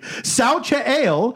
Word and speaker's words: Xochitl: Okay, so her name is pronounced, obviously, Xochitl Gomez. – Xochitl.